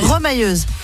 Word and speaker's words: remailleuse: Rémailleuse. – C'est ça, en rapport avec les mailles. – Remailleuse. – On dit, ça se prononce pas comme ça? Remailleuse? – Remailleuse, oui. – Ah, remailleuse, pardon.